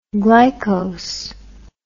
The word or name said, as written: glycose